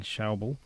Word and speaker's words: Schäuble: And apparently, they've had a press conference today, at which the German finance minister Wolfgang Schäuble and